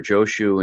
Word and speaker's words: Joshu: But as a segue, to help segue into the Eight Awakenings fascicle, let me cite something by the famous Chinese monk Jiaozhou, or Joshu in Japanese pronunciation,